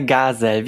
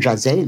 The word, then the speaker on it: Gazelle: Äh, Gazelle, wie Garten und Sellerie. Äh, weil ich habe manchmal Jazelle gehört im Podcast.